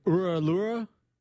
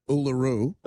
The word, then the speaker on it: Uluru: Uluru.